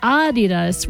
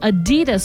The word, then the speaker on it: Adidas: It's supposed to be Adidas with a slight emphasis on the first syllable, not Adidas stressing the second.